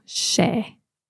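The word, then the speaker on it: 's e: So is e becomes apostrophe s rather than is. So apostrophe s and then e, and that then gets pronounced more like a sh sound, so she.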